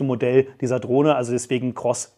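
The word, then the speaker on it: XROSS: Und genau, das ist eben die X, also Cross-Drohne heißt die, also X-Ross, werdet ihr im Titel auch sehen, wird aber als Cross ausgesprochen, also als Kreuz.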